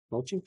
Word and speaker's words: mochi: is it mochi? Is that how you pronounce it? – Mochi. – Mochi. Mochi. I think.